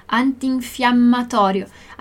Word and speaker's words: antinfiammatorio: Antinfiammatorio. Attenzione alle doppie, né? Doppie consonanti.